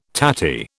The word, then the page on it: tatty: British English